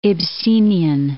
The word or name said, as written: Ibsenian